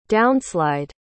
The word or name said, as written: downslide